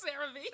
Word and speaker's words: CeraVe: How do you pronounce that? CeraVe? – CeraVe? I think it's CeraVe.